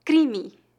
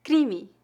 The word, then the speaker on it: crime: crime, crime, crime. Note que a vogal I nesta palavra é pronunciada de forma levemente nasal e a vogal E no final é pronunciada como um I fraco.